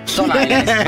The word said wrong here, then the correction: Stone Island: Ma perché scrivono? Scrivono come si legge. – Si chiama così, Stone Island.